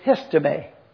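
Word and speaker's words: histeme (ἵστημι): now you need to tell those folk that that word is histeme. And so I wrote it out so that I could properly pronounce it for you. I jotted HISTAYMEE.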